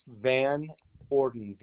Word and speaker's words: Van Orden: Yes, Ross Van Orden, 84790. – And you say your last name is Gordon? – No, Van Orden, VAN space capital ORDEN, two words.